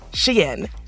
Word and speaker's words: Shein: Pronounced Shein, as in, she in trouble for those TikTok videos, as Madison Malone Kircher put it at the New York Times.